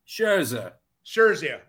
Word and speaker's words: Scherzer: Scherzer, whatever his name is, okay? – Scherzer.